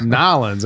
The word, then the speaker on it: New Orleans: Like, you're not saying it right. It's Nolans.